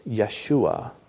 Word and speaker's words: Yeshua: But did you know there's another way to pronounce Joshua? If we pronounce it a little more true to the Hebrew, it sounds like this, Yeshua.